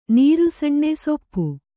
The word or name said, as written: ಸೂಪ